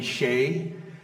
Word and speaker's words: Hsieh: Um, maybe some of you know this, the name here, Tony Hsieh. It's actually how you pronounce his name.